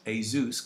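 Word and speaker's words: Jesús: Now, his actual name is pronounced Jesus because he's Spanish, but he lives in Luxembourg.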